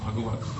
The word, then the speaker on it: Bhagavat: That second one should be Bhagavat with a short A.